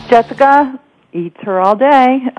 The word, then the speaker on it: Jessica Eturralde: Jessica Eturralde, Louisville Rediscovered.